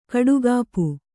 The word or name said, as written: ಕುಡಿಗಪ್ಪು